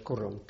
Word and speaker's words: Kurram: And the valley is known as Kurram. K-U-R-R-A-M.